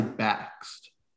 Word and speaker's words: Bakst: I say Bakst.